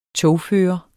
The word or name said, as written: togfører